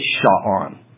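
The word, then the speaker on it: sha'an: It's sha'on. Phonetically, you spell it S-H-A-W-A-N. Sha'on.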